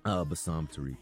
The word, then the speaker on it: Bassam Tariq: Bassam Tariq.